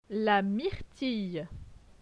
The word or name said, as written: La myrtille